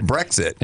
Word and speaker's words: Brexit: And speaking of that, the how do you pronounce that? – Brexit. – Brexit. – Yeah. – Brexit. – Yeah. – Like breakfast.